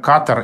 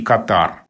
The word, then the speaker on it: Катар: Ну вот, например, катар и катар. В широком употреблении чаще можно встретить катар, а вариант катар был раньше известен в первую очередь специалистом по этой стране, по связям с ней. После того, как в этой стране было принято решение, что пройдет чемпионат мира по футболу, и это название стали употреблять чаще с таким ударением «Катар», оно как-то так вот больше закрепилось а, в речи, и, собственно, словарями он собственных отдает предпочтение ударению «Катар».